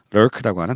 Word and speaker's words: lurk: 눈팅에 해당되는 단어가 바로 이 L-U-R-K 음. 발음도 어렵다는 lurk라고 하는 단어다. 알아두시면 되겠습니다. 발음이 상당히 까다롭다고 하네요. lurk 한번 발음해 주시겠어요? lurk만. lurk. lurk. lurk. 예. 이 발음. 한국 사람한테 상당히 까다로운 발음이죠. L, R 발음이 있어서.